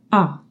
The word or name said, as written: a grave